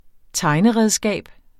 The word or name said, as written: tegneredskab